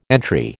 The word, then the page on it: entry: American English